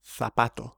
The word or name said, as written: zapato